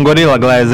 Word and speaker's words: Gorilla Glass: É o Golira Glass. Então, Gorilla Glass é o seguinte, ele foi a primeira. Vou lembrar disso pra sempre, cara. Eu falei certo agora.